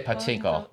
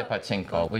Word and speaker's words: pachinko: Oh, bochinko. But we just say pachinko. We just say pachinko. We take the Japanese pronunciation.